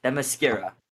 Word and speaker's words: Themyscira: Look, I'm gonna say it, and I'm gonna butcher it. Watch Themyscira. – You literally said it right.